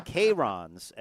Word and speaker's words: Chiron: Bugatti, Chirons, that is the correct pronunciation, Chiron.